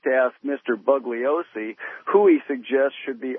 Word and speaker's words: Bugliosi: I mean, there was his book evokes so many questions, and that's certainly one that it would have been, and it's pronounced Bugliosi.